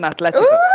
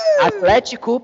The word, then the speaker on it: Atlético: So it's not even Atletico. Atlético Paranaense and Náutico. Thiago plays for Náutico, and Léo Pereira plays for Atlético.